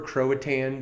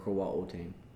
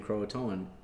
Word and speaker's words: Croatoan: It actually comes from the Algonquin word Croatoan, which means council town. So Croatan is just a hillbilly way of saying Croatoan.